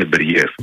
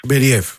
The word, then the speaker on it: Μπεριέφ: Μπέριεφ, πού τονίζεται πρώτα απ' όλα για να το λέμε και σωστά. – Νομίζω το λένε Μπεριέφ. – Μπεριέφ, λοιπόν. – Νομίζω τώρα είναι ναι. Από ό,τι έχω διαβάσει, τέλος πάντων, έχω δει να τονίζω στον Μπεριέφ.